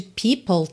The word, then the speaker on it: people: A gente tem P-E-O-P-L-E e a pronúncia people. Então, especialmente para quem tá começando, para quem tá vendo essa palavra aí pela primeira vez, atenção, tá? People. Então, you know how people are. Você já junta o L de people em are, porque o E final de people não é pronunciado, certo? O som termina no L, people. Com L, digamos, língua no céu da boca, tá?